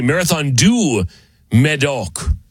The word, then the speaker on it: Marathon du Médoc: Marathon de Médoc. I'm sure I said that wrong. Or excuse me, Marathon du Médoc.